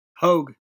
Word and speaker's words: Hoegg: Hoegg. – Hoegg. Hoegg, like almost like Hulk Hogan.